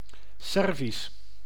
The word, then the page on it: Servisch: /ˈsɛr.vis/